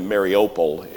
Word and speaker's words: Mariupol: The city of, well, most people pronounce it Mariupol. We've heard a lot about Mariupol. You know, really Mariupol is, I think that's the way it's to be pronounced.